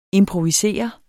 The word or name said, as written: Improvisere